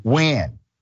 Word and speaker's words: Nguyen: Nguyen. It is spelled N-G-U-Y-E-N, but pronounced Nguyen.